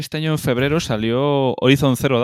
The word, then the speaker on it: Horizon: La H es muda, sí. Horizon.